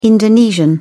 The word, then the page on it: indonesian: British English